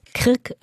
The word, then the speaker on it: Krk: Krk, Rab und Zres.